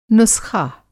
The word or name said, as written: नुस्ख़ा